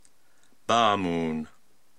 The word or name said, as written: Baamoon